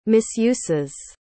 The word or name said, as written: misuses